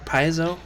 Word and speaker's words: Paizo: Oh, Paizo. Am I saying that right? Paizo? – I've heard it pronounced all the different ways. That's the way I've most commonly heard it.